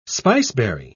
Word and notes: spiceberry: British English

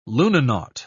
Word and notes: lunanaut: British English

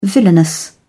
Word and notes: villainous: British English

